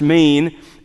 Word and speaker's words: Men: The primary worship of this city was of Mene, M-E-N, pronounced Mene, and Zeus.